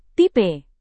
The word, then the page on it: tipe: Indonesian